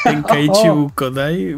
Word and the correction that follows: Budokai: Eh, Budokai, Ajá.